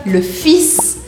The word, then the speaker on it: fils: E aí, o filho fica le fils. Presta atenção porque aqui você precisa pronunciar esse S.